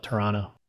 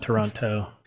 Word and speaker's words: Toronto: because they were pronouncing the T's, the last T in Toronto. – Oh. – Yeah, apparently locals in Canada call it Toronto. – Oh, okay. – Not Toronto.